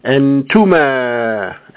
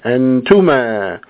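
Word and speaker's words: antuma (أنتما): and the sound is now, or it is pronounced, antuma, antuma.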